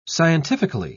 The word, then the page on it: scientifically: British English